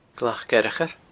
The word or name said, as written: glacfhearchair